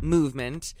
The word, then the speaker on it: MVMT: It's pronounced movement, sort of like MGMT, but for time telling.